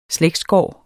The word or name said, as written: slægtsgård